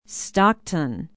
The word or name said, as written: stockton